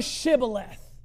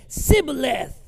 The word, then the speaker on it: Shibboleth: Then said they unto him, say now Shibboleth. And he said, Sibboleth. For he could not frame to pronounce it right.